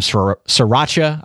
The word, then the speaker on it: sriracha: Water chestnuts, you know, some sriracha, I believe is the proper pronunciation of it. – Good job.